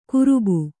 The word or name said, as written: ಕರೋಬಾ